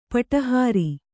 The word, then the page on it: ಪಟಹಾರಿ: Kannada